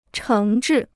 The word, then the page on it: 诚挚: chéng zhì